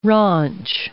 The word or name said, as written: raunch